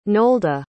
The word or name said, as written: nolde